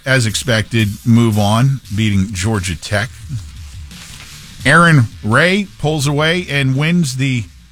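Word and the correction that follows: Rai: Rye. – Just Ray, remember, yes. Rye. Rhymes with guy.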